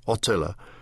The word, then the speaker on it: Attila: Attila, the stress in Hungary is on the first syllable, which is rounded until it is almost an O, Attila, is a common boy's name.